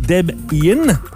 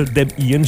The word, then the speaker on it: Debian: Le nom a combiné sa copine, à l'époque, qui s'appelait Deborah, et son nom à lui qui était Ian, donc Deb Ian. – Oh. – Donc, on serait supposé prononcer ça Deb Ian, j'imagine, mais on appelle ça Debian.